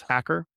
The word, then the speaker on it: Hacker: And that's spelled N-E-U-R-G-E-A-R. My email is Hacker, H-A-C-K-E-R.